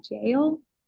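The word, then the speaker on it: Jael: And it was talking about, it's J-A-E-L. Is that jail pronounced? – I would say, yeah, that's how I would say it.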